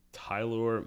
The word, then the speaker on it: Tylor: Rotation, as of right now, Max Scherzer, Chris Bassett, Carlos Carrasco, Taiwan Walker, Tylor McGill. – What is it? – T-Y-L-O-R, so Tylor. – Yeah, Tylor. – McGill, M-E-G-I-L-L.